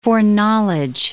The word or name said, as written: foreknowledge